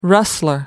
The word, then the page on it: rustler: American English